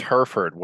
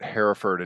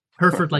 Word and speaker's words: Hereford: It's Hereford. I moved here, I pronounced it Hereford and people laughed at me. – Hereford like the cow.